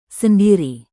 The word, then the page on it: sendiri: indonesian